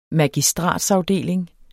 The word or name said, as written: MAGISTRATSAFDELINGEN